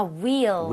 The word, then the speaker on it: wheel: 발음할 때 휠이 아니라 뭐라 그랬죠? – 아, wheel. – Wheel, right? 우리가 흰색도 white라고 안 하죠? – White. – White이라고 하죠. 그래서 네. H가 없는 느낌으로 발음할 때 wheel chair 이라고 하는 게 맞습니다. – Wheelchair. 근데 wheelchair. 그 있잖아요. W-H-O-L-E.